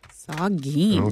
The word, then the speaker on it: Saugeen: S-A-U-G-E-E-N. – Saugeen. – Okay. – Yeah. – All right. – Like yeah. – Soggy wieners, but Saugeen.